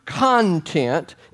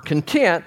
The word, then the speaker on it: content: yet it's so significant that the word content, emphasis on, word content, emphasis on the second syllable, is really the same Greek word as content, emphasis on the first syllable.